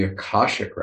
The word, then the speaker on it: Akashic: In the Akashic records, apparently, is the correct pronunciation.